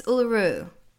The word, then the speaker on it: Uluru: Is it Uluru? – Yes, Uluru. Yeah. So Uluru is right in the middle of Australia, outside of Alice Springs. And you obviously can't climb Uluru anymore because it is a sacred site.